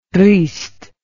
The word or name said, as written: reist